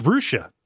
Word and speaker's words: Russia: Yeah, we have a town that is R-U-S-S-I-A. How would you pronounce that, Gary? – R-U-S-S-I-A. – Mm-hmm. – I might say Russia. – No, you clod, it's Rushi.